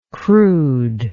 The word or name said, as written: crudeness